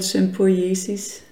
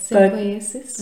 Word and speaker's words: sympoiesis: but Sympiesis. – See, actually, I don't know what it's called in English. In Danish, it's called sympoiesis. – Okay, sympoiesis.